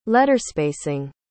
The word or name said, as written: letterspacing